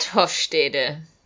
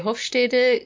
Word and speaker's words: Hofstede: So, Gert Hofstede, or I think people pronounce him Hofsted. I always say Hofstede because that's the German pronunciation of this last name.